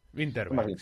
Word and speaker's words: Vinterberg: Vinterberg. Winterberg, no sé cómo se pronuncia. – Winterberg, sí, bueno.